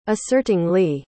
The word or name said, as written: assertingly